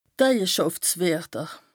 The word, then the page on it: D' Eijeschàftswerter: Bas Rhin; Strasbourg